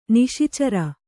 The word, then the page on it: ನಿಶಿಚರ: niśi cara